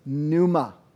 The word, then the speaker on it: pneuma: The Greek for it is pneuma. Pneuma. And it has a kind of a silent P on the front.